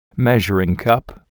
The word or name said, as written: measuring cup